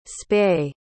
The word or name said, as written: spey